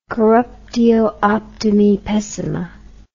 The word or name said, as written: corruptio optimi pessima